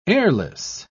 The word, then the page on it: heirless: British English